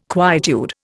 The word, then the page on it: quietude: Indian